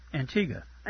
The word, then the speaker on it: Antigua: Is it on Antigua? – Antigua. – Antigua. – Yeah. – I don't know why I want to put an extra syllable in there.